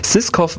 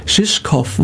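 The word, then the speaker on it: Žižkov: Ja, also der Künstler heißt tatsächlich Valentin Vogt, ist ein Singer-Songwriter hier aus der Gegend und der Titel heißt Shishkov Waltz. – Shishkov Waltz, Shishkov, okay, also komplett falsch ausgesprochen.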